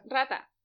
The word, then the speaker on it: rata: Rata? – Rata.